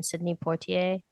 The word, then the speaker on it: Sidney Poitier: like Henry Belafonte and Sidney Poitier.